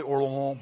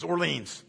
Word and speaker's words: Orléans: I know it's pronounced probably Orléans, but I'm not French. You'll have to just take the English pronunciations. Orleans, okay? Like, or New Orleans, whatever, right?